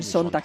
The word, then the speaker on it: Sonntag: Schönen Sonntag.